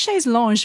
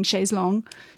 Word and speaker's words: chaise longue: Chaise Longue. Not Chaise Longue, which is how I've always been mispronouncing it. But yeah, now I'm committed to saying Chaise Longue.